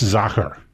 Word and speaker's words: Sacher: And how do you spell Zacher? – S-A-C-H-E-R. – S-A... – C-H-E-R. Yeah, it's Zacher. – Okay, I got it. – It's a German pronunciation.